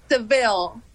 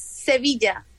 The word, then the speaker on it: Sevilla: Seville. Well, in Spanish is Sevilla, but in English is Seville, so it's okay.